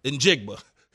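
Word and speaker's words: Njigba: In Jigba.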